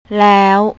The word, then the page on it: law: thai